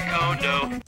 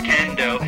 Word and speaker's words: Kondo: Like how to say Kondo. – He says it right. He says Kondo. Yeah, he does say Kondo. Yeah. – It's not pronounced Kendo.